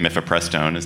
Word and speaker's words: mifepristone: It concerned, I'm going to say it, mifepristone. Did I say it correctly? – Excellent.